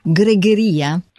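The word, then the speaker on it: greguería: gregheria? – Esatto.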